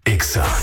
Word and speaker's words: Exa: No se dicen éxitos. Se dice Exa.